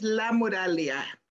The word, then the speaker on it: Lamuraglia: My last name is Lamuraglia. It has a G, but the G is silent, okay?